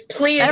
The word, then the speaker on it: Pleiades: However, the reveal that Plaizuan Shore was their castle, that totally floored me, and I had no idea. That Yeah, he did. It's Pleiades. – Everyone else said it wrong. Pleiades.